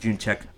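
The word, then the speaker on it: Jędrzejczyk: J-E-D-R-Z-E-J-C-Z-Y-K. – Yeah, it's like June Check.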